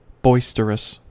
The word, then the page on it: boisterous: American English